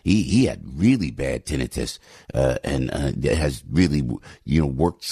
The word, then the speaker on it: tinnitus: It's pronounced tinnitus, not tinnitus.